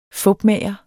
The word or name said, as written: fupmager